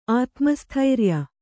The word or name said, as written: ಆತ್ಮಸ್ಥೈರ್ಯ